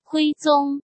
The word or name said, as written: Emperor Huizong